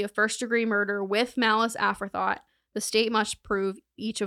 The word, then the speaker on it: aforethought: And then they proceeded to ask them about malice afterthought. Aforethought is how you pronounce that.